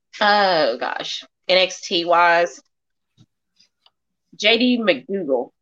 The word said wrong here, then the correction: McDonough: Not McDougal. Not McDougal. – JD McDonough? Is that how you say it? – McDonough, yeah. – It. Yeah, I've always said McDonough. It's but if McDonough. It isn't McDougal, she's saying McDougal.